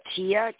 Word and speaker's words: Gia: Hi, it's uh, Gia.